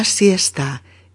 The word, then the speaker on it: siesta: Bien, amigas y amigos, estamos hablando de la siesta S-I-E-S-T-A. Siesta. Con diptongo en la primera sílaba. Fijaos, ¿eh? es Una sílaba. Siesta. Palabra de dos sílabas.